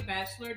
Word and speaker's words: Batchelor: So my name is Kimberly Batchelor Davis.